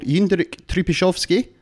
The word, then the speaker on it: Jindřich Trpišovský: And their manager is called Jindřich Trpišovský, and that is how you pronounce that. I've asked an actual Czech person.